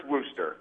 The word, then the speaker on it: Worcester: If you're not from Worcester, it's Worcester. – Yeah. It's Worcester, because I was there, and they said, it's not Worcester, it's Worcester.